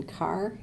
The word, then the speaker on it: Carr: Yes, my name is Joanne Carr, and that is spelled C-A-R-R.